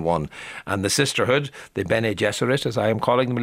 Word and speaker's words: Bene Gesserit: How do we say that rather mysterious group of nuns? Bene Gesserit, is that? – Oh, well, good for you.